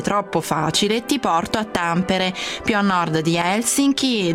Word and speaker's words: Tampere: Ciao, allora prima di tutto Tampere, quindi l'accento va proprio all'inizio.